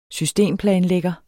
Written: systemplanlægger

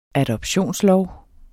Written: adoptionslov

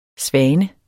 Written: svane

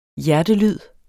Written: hjertelyd

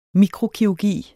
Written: mikrokirurgi

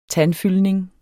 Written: tandfyldning

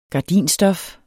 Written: gardinstof

